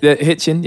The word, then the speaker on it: Hitchin: How you pronounce it? – Hitchin, yes indeed.